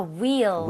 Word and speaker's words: wheel: 발음할 때 휠이 아니라 뭐라 그랬죠? – 아, wheel. – wheel, right? 우리가 흰색도 white라고 안 하죠? – white. – white이라고 하죠. 그래서 네. h가 없는 느낌으로 발음할 때 wheel chair. 이라고 하는 게 맞습니다. – Wheelchair. 근데 그 있잖아요. W-H-O-L-E.